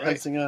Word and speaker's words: Helsingør: Helsingør, is that Helsingør. – Right? Helsingør. Oh, of course. I say it wrong.